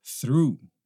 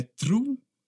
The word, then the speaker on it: through: Through. Through. Through. Não é true. Não é trau. É through. Through.